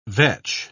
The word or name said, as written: vetch